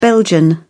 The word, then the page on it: belgian: British English